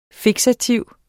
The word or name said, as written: fiksativ